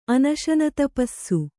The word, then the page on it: ಅನಶನತಪಸ್ಸು: Kannada